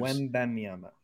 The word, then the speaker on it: Wembanyama: How do you pronounce the name? Wanabaya? – Wembanyama. – Whatever the hell his name is. He was shaking him out of his shoes. – Wembanyama.